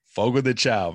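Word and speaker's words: Fogo de Chão: Now, we were having dinner the other day at, help me pronounce it, Fogo de Chao. – Fogo de Chao, man. Yes.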